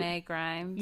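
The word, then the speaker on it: Shenae Grimes: it's like Shayna Grimes? Is that how you say your name? It's like the girl from Beverly Hills reboot. – Shanae Grimes.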